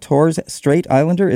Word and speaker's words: Torres Strait Islander: Torres Strait Islander, is that the correct way to say that? – That's correct, yeah.